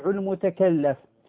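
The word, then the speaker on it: المتكلَّف: الذي كنت أريد ذكره وإن جاء متأخرا هو أنني في الدرس السابق ذكرت لفظة أخطأت في النطق فيها وكررتها مرارا، وهي متكلف، فذكرت السجع المتكلف، وإنما هو السجع المتكلف. فنقول معنى متكلف وسجع المتكلف بفتح اللام على أنها من على أنها اسم مفعول، مفهوم؟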